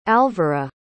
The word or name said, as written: alvara